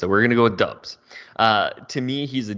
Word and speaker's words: Doubs: Yeah, and we're going to call him Romeo Doubs because every pronunciation guide I can find has him uh, as Doubs.